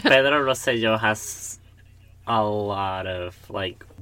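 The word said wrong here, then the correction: Rosselló: gotta correct me on my pronunciation, man. – Rosello. – Rosello. Okay. – God, I hate you both. – Because you can't roll your R's.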